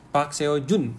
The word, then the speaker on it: Park Seo-jun: Ini harusnya cukup meramaikan bioskop Indonesia karena aktor yang bermain di dalam The Divine Fury ini cukup ternama nih, yaitu Opa Park Seon-jun ya. Bacanya gimana sih ini? Park Seo-jun. Ya.